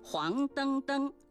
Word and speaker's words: 黄澄澄: 黄澄澄属于叠词结构，那么黄澄澄的澄是不是它的第三种读音呢？不是的，黄澄澄属于叠词结构，后两个叠字登登遵循汉语的语音习惯发生变调，读作音平登，因此整体注音是黄登登，而非原调的黄登登或是黄澄澄。